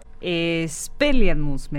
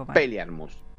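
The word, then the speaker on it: Expelliarmus: Espeliarmus me parece que es, si no me equivoco. Espeliarmus.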